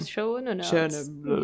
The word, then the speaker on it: Schoenaerts: That one with Tilda Swinton and Matthew Schoenaerts. I think that's how you say his name. – Schoenaerts. – Yeah.